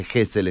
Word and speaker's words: Gesell: Gesell es la aproximación a la pronunciación, pero se dice Gesell en general acá.